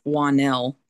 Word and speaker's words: Juannell: It's Juannell, J U A N N E L L.